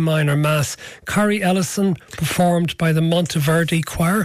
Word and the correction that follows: Kyrie Eleison: The Kyrie Eleison. – Oh, there you go. I got that completely wrong.